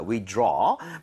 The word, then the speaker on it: withdraw: withdraw. 발음할 때는 draw가 아니고요. aw라는 스펠링은 보통은 입 모양을 벌려주셔야 돼요.